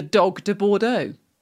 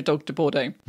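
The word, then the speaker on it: Dogue de Bordeaux: I was like, how do you pronounce that? Well, literally as it looks, the dog de Bordeaux. That's how you say it. It's literally dog de Bordeaux.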